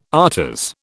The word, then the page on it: afters: British English